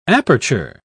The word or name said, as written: aperture